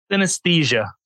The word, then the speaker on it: synesthesia: S-Y-N-E-S-T-H-E. S-I-A. – Synesthesia.